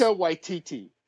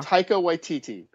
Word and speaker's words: Taika Waititi: Taika Waititi. – Uh-huh. – Taika Waititi.